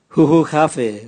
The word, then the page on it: huhugave: Raga